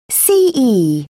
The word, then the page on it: ce: British English